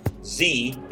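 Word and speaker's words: Xe: And now they just go with Z. It's spelled X-E, by the way, so people don't even really know how to pronounce it.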